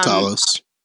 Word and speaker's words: Talos: Talos.